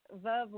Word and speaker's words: Veuve: Veuve like love. Remember it like that.